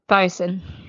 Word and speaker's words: boatswain: Garrick, his first mate, the boatswain. – No, bosun. – Bosun? Is that how you say it? – Yeah, bosun. – Boatswain. – No, mate, bosun. – Bosun. – That's right, isn't it, Sarah? – Is it bosun? – It's bosun, isn't it? – I don't know.